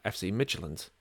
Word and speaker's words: FC Midtjylland: You look at Bill Hamid's former team, FC Midtjylland, which I think I pronounced right. I think that's how I pronounce it. – I think that's, we'll go with it.